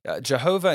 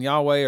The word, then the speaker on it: Yahweh: Jehovah and Yahweh are just two different ways of pronouncing it. Scholars nowadays think Yahweh is probably the correct way, but it used to be thought that Jehovah was the correct way to say the Lord's name.